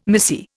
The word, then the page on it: missy: Indian